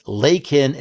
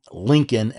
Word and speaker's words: Laken: It's Lakin, L-A-K-E-N, not Lincoln, L-I-N-C-O-L-N.